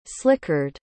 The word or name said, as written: slickered